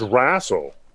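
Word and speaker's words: RASL: And I pronounce it as Razzle.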